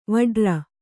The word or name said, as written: ವಟ್ಟಾರ